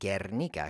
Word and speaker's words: Guernica: It's called Guernica because that U is silent. It's just serving the function of forcing the hard G sound.